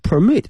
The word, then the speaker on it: permit: 同学们同时注意一下呢，这个许可这个词呢 P-E-R-M-I-T 呢它的发音不是 permit， permit 是动词，而名词的话是 permit，